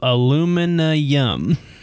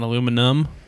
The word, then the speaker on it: aluminium: It says a Citra hopped galaxy wrapped in solar free system of aluminium. Aluminium. Oh, it's aluminium. I got the yum part. I was trying to figure out how to pronounce it. Aluminium. Like not aluminum, but with yum.